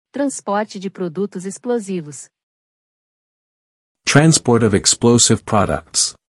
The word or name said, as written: Transporte de produtos explosivos